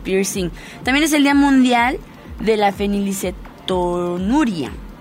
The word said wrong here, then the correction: fenilcetonuria: Sí lo dije bien, sí, fenilcetonuria.